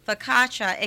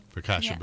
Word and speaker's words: focaccia: Focaccia bread. – Yes. I always thought I thought the O's were like long O's and that focaccia. It's just focaccia? – That's how I say it.